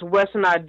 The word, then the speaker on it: Whisonant: It's C-H-A-N-T-E-N. Then it's Wissanott, W-H-I-S-O-N-A-N-T.